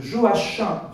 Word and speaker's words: Joachim: Je fais exprès de dire Joachim du Bellay parce que souvent on prononce mal. C'est un français, hein, les CH se prononcent CH.